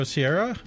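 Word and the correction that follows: Serra: or Sarah, rather, Sarah.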